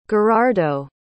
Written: gherardo

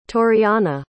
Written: toriana

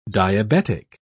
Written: diabetic

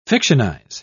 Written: fictionize

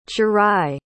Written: ciarrai

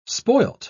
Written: spoiled

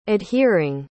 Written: adhering

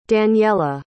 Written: daniela

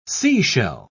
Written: seashell

